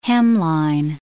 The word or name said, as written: hemline